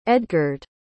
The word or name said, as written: edgard